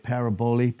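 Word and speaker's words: parabole: So for us to understand this particular parable here, and a parable is from the Greek where we get paraboli, parable. It's P-A-R-A-B-O-L-E, long E in the Greek.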